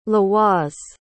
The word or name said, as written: laoise